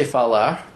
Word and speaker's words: falar: It's interesting, some Portuguese Rs in the south, and even as far north as São Paulo, which is still kind of south, they pronounce the R like an English R, like the verb to speak, which would be falar, F-A-L-A-R. Like I say falar, because that's the right way to say it, but someone from São Paulo will very often say falar.